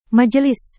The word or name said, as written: majelis